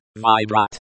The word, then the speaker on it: Выбрать: Pronounced Thai means U-M-O-J-E-S-H-I-E-R-I. Можешь. Pronounced Mojesh means can or may vayeru beratayeri. Выбрать. Pronounced vibrat means to choose k-r-i-s-n-u-u. Красную. Pronounced krasnuyu means rederu beashakau. Rubashku. Pronounced rubashku means shirt s-e-n-u-u.